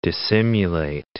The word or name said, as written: dissimulate